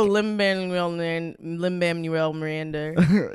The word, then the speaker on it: Lin-Manuel Miranda: How do you say his name? – Lin-Manuel Miranda. But I call him Hamilton.